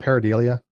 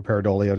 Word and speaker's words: pareidolia: Yeah, are you familiar with the what's called pareidolia? Or pareidolia, depending on how you would pronounce it.